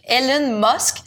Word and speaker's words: Elon Musk: Oh, le coquin Elon Musk. Ça, je l'ai-tu bien prononcé? – Tu l'as bien dit.